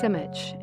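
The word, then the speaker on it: Simić: And I think it's a great idea to share something by Charles Simic, who was born in Belgrade in 1938. Actually, his name is pronounced Simic in Serbian, and who died last year.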